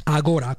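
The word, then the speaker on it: Agorà: agora con l'accento sulla A, sulla A.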